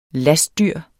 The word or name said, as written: lastdyr